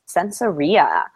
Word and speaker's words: Sensoria: Yeah, it's actually pronounced Sensoria, which is hard to...